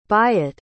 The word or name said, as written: byatt